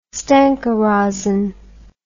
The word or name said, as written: Stenka Razin